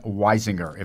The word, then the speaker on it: Weisinger: Tell us about Mort Weisinger, if I've pronounced his name right. – You pronounced it right.